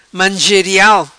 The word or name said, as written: Mangerial